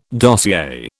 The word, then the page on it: dossier: British English